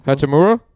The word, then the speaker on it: Hachimura: how do you say the last name? – Hachimura.